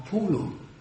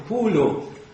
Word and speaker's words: Pulu: Och om ni läser de här svåra namnen, så om det stavas med U så uttalar ni det som O. Och är det skrivet med O så uttalar ni det som Å. Annars blir det väldigt tokigt. Han heter inte Pulu utan Polo.